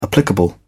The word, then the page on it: applicable: British English